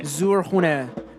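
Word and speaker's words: Zurkhaneh: Is that how you say it? Zurkhane. – Zurkhane. Means powerhouse.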